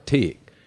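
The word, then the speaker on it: Teague: And so, but my grandfather and, you know, the family, we have a farm out in Texas, a small town called Tigg. – Tigg? – Tigg. It's spelled, it's T-E-A-G-U-E pronounced. – Oh, like some sort of weird Irish, like pig.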